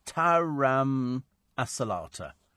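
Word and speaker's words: taramasalata: A lot of people telling me about Tarasamalata. It's Taram Asalata.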